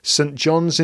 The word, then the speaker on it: St. John's: But we're talking about South Korea, New Mexico, St. John's in Newfoundland.